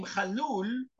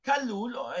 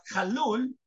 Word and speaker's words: Khalloul: And my family name, Khalloul, or Kallul in English, you cannot pronounce the Kha, but Khalloul